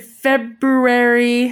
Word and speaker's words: February: I know everyone says February, but it's February. – Well, leave off the R. It's silent. – February. – It's February. The only word with a silent R in it, unless you're from Boston.